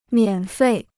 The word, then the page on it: 免费: miǎn fèi